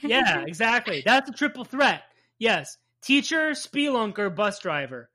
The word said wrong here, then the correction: spelunker: Why do you say it so weird? It's spelunker. – There's an E in there. I want to pronounce the E as a spee.